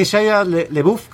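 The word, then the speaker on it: Shia LaBeouf: Quisiera que Shia LeBouf, que se pronuncia así, sí que me gritaba el oído cada dos por tres, just do it, mientras no puedo resolver un problema.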